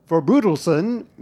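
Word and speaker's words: Forbrydelsen: Well, this week on Twitter, listener Deborah tweeted me to point out that Forbrudelsen is Danish and not to pronounce it breed, but brood, and then pronounce how it looks there.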